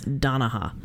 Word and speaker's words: Donaha: Donahanna Park in Yadkin County. – Hmm. – I said that wrong. It's not Donahanna. It's Donaha. – Donaha. – I put an extra like, nah.